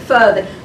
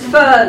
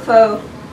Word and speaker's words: pho: And the reviewer added a note to readers explaining that you should in fact pronounce it pho. The journalists had just figured that out, so they wanted to spread the information. It's pho, not pho.